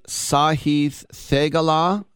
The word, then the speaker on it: Sahith Theegala: The feel-good story, Sahith Theegala.